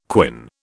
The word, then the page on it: quin: British English